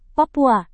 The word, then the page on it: papua: Indonesian